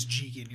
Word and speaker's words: Jigen: Jigen and Goemon. – Jigen and Goemon. – Yeah, it's Jigen. You're right. I've been kind of mispronouncing it. Because they say in the Jigen.